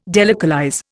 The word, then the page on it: delocalize: Indian